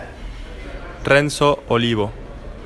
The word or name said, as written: Renzo Olivo